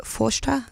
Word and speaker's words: Phaiste: That song's called Forster. It's spelled P-H-A-I-S-T-E if you want to go and look it up and listen to it again, which means child in Irish.